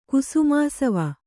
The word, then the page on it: ಕುಸುಮಾಸವ: kannada